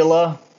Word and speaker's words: Avila: I forget how to pronounce his last name. Avila.